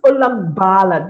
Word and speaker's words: Olagbala: You know, I've had friends who say, okay, hi, Safia. I was like, what? It's Olagbala.